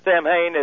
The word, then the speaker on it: Samhain: Samhain is spelled S-A-M-A-N or S-A-M-H-A-I-N.